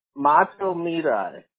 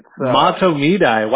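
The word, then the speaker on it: Mahtomedi: Mato Midai. – Mato Midai. Wow, that is so like a very phonetic with like a long I there. I didn't expect a long I at the end.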